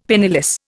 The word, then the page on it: penniless: Indian